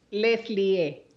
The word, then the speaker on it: Leslie: Leslie, ajá.